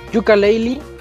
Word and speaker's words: Yooka-Laylee: Sí, eh, y comenzamos hoy con Yujin, que nos va a platicar sobre la reseña de Yuka ¿Cómo se pronuncia? ¿Yoka o Yuka? Yuka Leili.